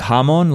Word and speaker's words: hamon: Technically, it's like hamon. Like it's you accent the first syllable more, but we end up just saying hamon.